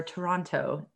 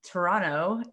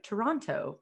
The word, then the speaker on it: Toronto: So when I moved up here originally, I was like Toronto, because I thought one is supposed to pronounce T's. – Not when you're in Canada. – But apparently not when you're in Canada. Yeah.